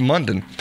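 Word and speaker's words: Mondon: It is rhymes with smile. So, yeah, Smile Monday. It's Munden. Rhymes with London, according to that.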